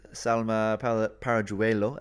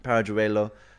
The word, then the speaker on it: Paralluelo: But then the impact substitute, Salma Paralluelo, as we've been told it's how to say it, um, Paralluelo,